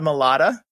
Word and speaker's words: Malott: I'm under Alotta Malotta, which is terribly hard to spell, but it rhymes with my last name.